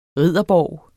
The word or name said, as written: ridderborg